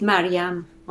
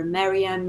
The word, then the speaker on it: Maryam: So in Farsi, it is Maryam, okay? But then I answer to whatever, Maryam, you know, depending on which country I lived in, people pronounce it differently, and I answer to all of them.